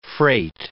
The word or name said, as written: freight